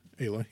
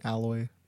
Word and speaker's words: Aloy: Is that really how it's pronounced? – Aloy, yeah. – I always keep saying alloy in my head. – That's two L's.